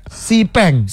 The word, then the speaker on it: Xpeng: Bacanya Xpeng atau Xpeng sih? – Sipeng. – Sepeng. – Oh, namanya Sipeng.